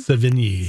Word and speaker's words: Sevigny: And Chloe Sevigny, am I saying her name right? – Sevigny.